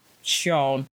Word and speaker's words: Xion: Now if you see his name written, it's spelled X-I-O-N. But apparently, especially in Korean, it's supposed to be pronounced Sion. So just to let y'all know, because it's not spelled how you pronounce it.